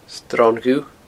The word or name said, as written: Sron Dhubh